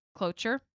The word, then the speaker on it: cloture: also known as cloture. Cloture? – Cloture.